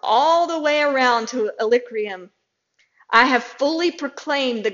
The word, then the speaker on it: Illyricum: That's how you say it. Illyricum.